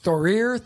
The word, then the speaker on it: Thorir: Thorir Thorbjørnsson, okay? So Thorir is T-H-O-R-I-R.